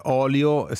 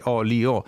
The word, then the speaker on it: olio: olio si deve sentire, si devono sentire, no, le tre sillabe, olio,